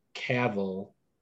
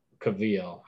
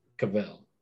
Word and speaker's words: Cavill: And then right before the podcast, I read that Henry Cavill, I think, he... – Cavill. – I always say it wrong. Cavill.